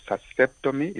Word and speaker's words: vasectomy: Vasectomy, is that what it is? – Perfect.